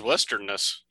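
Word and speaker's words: Westernesse: I thought it was Westernness. I Yep, that's right.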